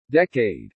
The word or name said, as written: decade